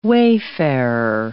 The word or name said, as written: wayfarer